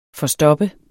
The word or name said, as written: forstopper